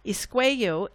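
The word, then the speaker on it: Iskwew: It is called Isqueyo. Isqueyo, it's spelled Iskweew. It is a Cree word for woman.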